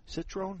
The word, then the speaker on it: Citroën: Citroën. That's how they pronounce them.